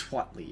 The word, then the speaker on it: Huatli: I believe it's Huatli, yes. – Yes, I think it's Huatli. – That is at least the pronunciation that I have now heard and have established as the one that I believe.